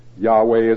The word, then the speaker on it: Yahweh: Yahweh is how it's commonly pronounced.